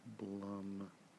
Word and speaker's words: Blumhouse: Blumhouse. Rhymes with plum. So Blum, yes. – Okay. – There are many people that say it that rhymes with plume as well, so either one is fine.